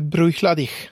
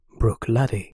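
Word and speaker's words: Bruichladdich: Jag tror att det är så att man även uttalar slutet så att det är Bruchladich. – Vi är hemskt ledsna att bryta programmet för ett klargörande. Rätt uttal är Bruchladdy.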